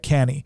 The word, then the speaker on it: Cannae: It looks like Cannae, but it's actually pronounced uh, Cannae.